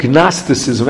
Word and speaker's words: Gnosticism: The mistakes that we spoke about until now have to do with what we call Shtei Rishayos, which is, it's called Gnosticism. Actually, you don't pronounce the G at the beginning, but the belief in more than one God.